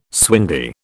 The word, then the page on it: swingy: British English